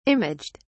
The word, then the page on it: imaged: British English